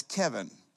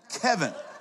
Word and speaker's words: Kevin: I said, little girl, my name is Kevin. Rhymes with heaven. That's where I'm going. Kevin.